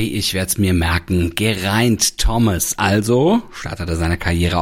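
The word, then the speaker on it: Geraint: Das ist nämlich ein walisischer Vorname und den spricht man tatsächlich fast so aus, wie man ihn schreibt. Gereint. Auf YouTube habe ich eine kleine Aussprachehilfe für dich gefunden. – Ger is in egg, reint is in pint.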